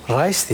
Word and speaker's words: Reis: Reis diye mi okunuyor? Hı hı.